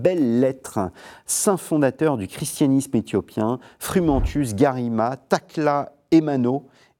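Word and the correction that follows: Tekle Haymanot: et Evostateios. – Tekla Aymanot et Eostateos.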